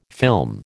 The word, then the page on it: film: American English